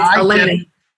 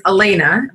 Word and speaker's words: Alana: So it's Alana.